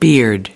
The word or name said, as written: beard